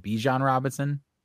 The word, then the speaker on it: Bijan Robinson: Is it Bijan Robinson? – Yes.